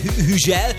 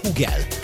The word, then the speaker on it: Hugel: Francia nyelvterületen Hügel, amúgy pedig nagyon durva, de Hugel a helyes kiejtés.